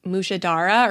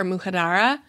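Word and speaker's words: mujaddara: Okay, I think what your mom was trying to make was I think it's pronounced mushadara or mujadara.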